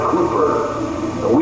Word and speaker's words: Cowper: Then my second one is the song which was written by the English poet William Cowper, C-O-W-P-E-R. In England, I understand they pronounce it Cooper. We pronounce it Cowper.